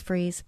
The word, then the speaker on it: Fries: And some people call me Miss Fries, and that's okay, but it's Freeze.